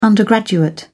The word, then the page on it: undergraduate: British English